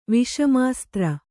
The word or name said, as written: ವಿಷಮಾಸ್ತ್ರ